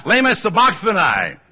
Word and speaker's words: lema sabachthani: lema sabachthani. How do you say this? Yeah, lema sabachthani.